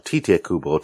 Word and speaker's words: Tite Kubo: I mean, Usher and Tora is one of the series that inspired Tite Kubo, or Tite Kubo, to give him the correct pronunciation.